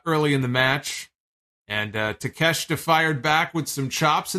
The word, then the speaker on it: Takeshita: Or Takeshita, I'm sorry. Jesus Christ. I was trying to say the last name earlier as I was writing his name down in my notes. For whatever reason, I couldn't say the last name. I just couldn't get it. Couldn't do it. It was Taz, I think, on commentary also was botching the pronunciation of the name. It was Takeshita, though.